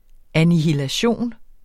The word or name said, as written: annihilation